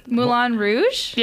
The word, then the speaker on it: Moulin Rouge: Moulin Rouge? – Yeah, thank you.